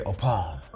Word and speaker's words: Opong: Well, the Ghanaians will say Opong. They'll leave out the G and just say Opong.